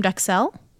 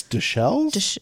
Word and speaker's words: duxelles: Mushroom duxelle. Is that how you say it? – I think it's duxelles.